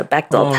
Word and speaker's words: Bechdel: Is it the Bechdel? – Bechdel test.